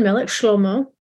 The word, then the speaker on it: Melech Shlomo: We know that King Solomon, Melech Shlomo, is how you say his name in Hebrew.